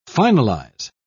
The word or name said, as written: finalize